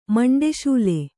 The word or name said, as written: ಮಂಡೆಶೂಲೆ